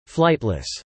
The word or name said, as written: flightless